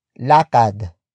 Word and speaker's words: lakad: lakad ends with d which is switched into r before adding the in suffix let's use it in a sentence Lakarin natin ang bangketa.